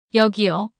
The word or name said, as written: 여기요